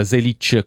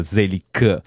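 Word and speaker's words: Zelić: E questa appunto era Lucy Zelic, che tra l'altro lei si fa chiamare con il cognome con la pronuncia giusta, Zelic con la C dolce, mentre invece in Australia si tende con i nomi che vengono appunto di origine slava di pronunciarlo dura, Zelic, come appunto Tomic.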